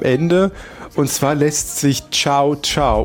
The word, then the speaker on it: Cao Cao: Das würde ich nun mal eigentlich Kakao aussprechen, aber es wird wirklich Cao Cao.